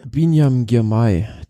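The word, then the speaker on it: Biniam Girmay: Also am Ende war es dann eine Massenankunft mit, wie spricht man den genau so aus? Binyam Girmay? – Binyam Girmay. Der englische Kommentator sagte Beanie being back.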